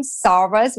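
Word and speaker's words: Szarvas: When I had still my maiden name, Sarvas, which is spelled S-Z-A-R-V-A-S, I had the most exotic kind of names coming out.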